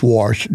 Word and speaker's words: Warsh: It's Warsh, W-A-R-S-H. I didn't mispronounce like George Washington. It's Warsh. He will take over when Powell's term ends in May.